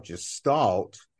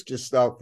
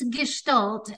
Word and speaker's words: Gestalt: What prompted you to the practice of gestalt, and specifically equine am I pronouncing it right, Gestalt, and explain to us what is Gestalt therapy and the role of horses in this practice. – Sure. And it's Gestalt, actually.